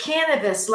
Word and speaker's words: Kanavos: It's Kanavos, like cannabis that you smoke, but with a V.